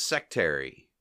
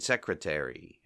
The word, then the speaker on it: secretary: Moe pronounces "sectary." Like, "Get me my sectary." You know, Larry says, "You mean secretary."